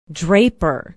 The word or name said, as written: draper